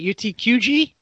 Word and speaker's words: UTQG: Yes, we should mention that the UTG wear rate. What UTG? Did I say that right? UTQG.